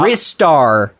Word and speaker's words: Ristar: the official pronunciation, as dictated by one is shot. – Ristar. Like, yeah, yeah, by one shot.